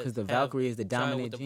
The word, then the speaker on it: Valkyrie: Is it Valkyrie? How you say it? – Valkyrie.